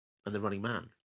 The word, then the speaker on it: The Running Man: You put the emphasis on the wrong word there. – Which one should I have put the emphasis on? – It's Running, the running it's Man. The Running Man. It's the not running The Running Man.